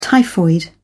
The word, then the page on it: typhoid fever: British English